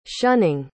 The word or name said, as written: shunning